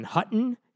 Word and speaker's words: Houghton: Hutton University. – H-U-T-T-O-N? – H-O-U-G-H-T-O-N.